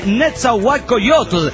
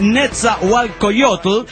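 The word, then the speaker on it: Nezahualcóyotl: Se lee Netzahualcoyotl. Es adicto al hentai, gracias. Gracias, hermano, me emociono en este acto porque ya me estaban tronando de pendejo, ¿no? Ah, no sabe pronunciar Netzahualcoyotl y toda la mamada. Es Netzahualcoyotl y está bien pinche jodido.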